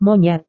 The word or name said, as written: monyet